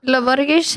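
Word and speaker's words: Lô Borges: Og hvordan sier man Loborges? Loborges? – Loborges, hørte det ut Milton Nascimento.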